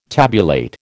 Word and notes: tabulate: American English

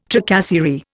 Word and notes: tracasserie: Indian

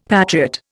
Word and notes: patriot: Indian

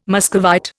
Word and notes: Muscovite: Indian

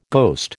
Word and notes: ghost: American English

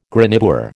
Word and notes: graniteware: American English

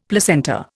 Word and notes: placenta: Indian

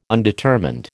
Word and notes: undetermined: American English

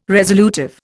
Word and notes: resolutive: Indian

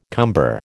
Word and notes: cumber: American English

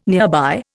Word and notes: nearby: Indian

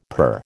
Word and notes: per: American English

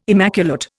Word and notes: immaculate: Indian

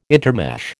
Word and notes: intermesh: American English

